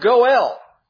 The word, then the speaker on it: goel: And it's the word goel. In English, we would spell it G-O-E-L. Goel. Two syllables. G-O-E-L. Goel.